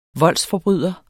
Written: VOLDSFORBRYDERNES

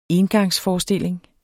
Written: ENGANGSFORESTILLING

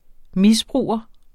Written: misbruger

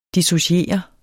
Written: dissociere

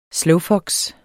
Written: slowfox

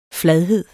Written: fladhed

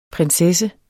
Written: prinsesse

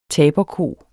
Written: taberko

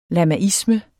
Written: lamaisme

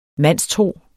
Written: mandstro

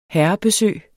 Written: herrebesøg

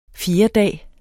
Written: fjerdedag

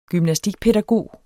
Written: gymnastikpædagog

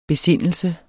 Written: besindelse

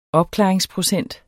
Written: opklaringsprocent

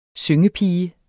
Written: syngepige

